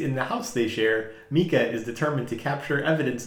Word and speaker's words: Micah: Spelled like Micah. – Pronounced Mika.